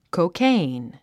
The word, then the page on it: cocaine: koukéin; コォケェイン